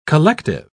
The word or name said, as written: collective